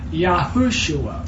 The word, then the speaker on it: Yahushua: The Savior's name is Yahushua, four syllables. Yahshua is three syllables, and it's the Hebrew word for salvation.